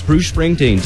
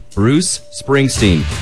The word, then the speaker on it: Bruce Springsteen: Coming up the rest of the hour, Collective Soul Stained, Social Distortion, Scorpions, Dire Straits, Bruce Springsteen. Just kind of giving You know what? I'm butchering that one. I can say it normally. Maybe I'm just too excited. One more time. Bruce Springsteen.